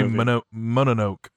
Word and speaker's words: Mononoke: I also watched Princess Mononoke. – Yay! – Oh, yes. – I think it's actually Mononoke is how it's pronounced. – Yes. Mononoke. – Like Roanoke? – That movie is so good.